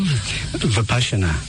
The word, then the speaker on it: Vipassana: Vipassana.